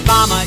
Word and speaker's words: llama: Llama is spelled L-L-A-M-A, but it's pronounced llama. The first L is silent, or maybe it's the second L, I'm not sure.